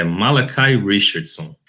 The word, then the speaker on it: Malachi Richardson: E aquela já que todo mundo gosta de reclamar da maneira que a gente pronuncia os nomes dos jogadores, é Malachi Richardson.